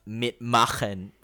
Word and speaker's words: Mitmachen: Yes, it's actually pronounced Mitmachen, by the way, but it's cool.